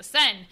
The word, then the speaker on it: Seine: I had to think, or the Seine. Yeah, the Seine. That's how you say it, the Seine.